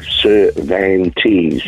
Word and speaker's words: Cervantes: Cervantes.